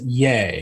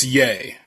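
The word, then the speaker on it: Ye: Well, he pronounces it Yay. – It's Yay. Yeah, Yay.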